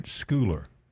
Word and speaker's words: Scoular: Now, when you're Schuller, it's spelled S-C-O-U-L-A-R, right? Schuller. – Yes, sir.